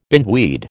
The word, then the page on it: bindweed: American English